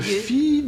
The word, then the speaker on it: fils: Donc, ces fils qu'il faut prononcer et ces fils que l'on prononce au moins depuis la seconde moitié depuis le milieu, si vous voulez, du 19e siècle. Voyez-vous? Mais la prononciation ancienne, c'était fi.